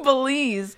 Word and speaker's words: Belize: Belize. – Yeah. – Pronounce the country. It's Belize.